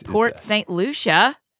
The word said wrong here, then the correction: Port St. Lucie: What? Port St. Lucie? – Lucy, yeah. Spelled like Lucia.